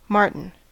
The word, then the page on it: marten: American English